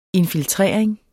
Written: infiltrering

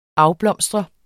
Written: afblomstre

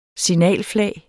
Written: SIGNALFLAG

